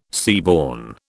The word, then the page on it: seaborne: British English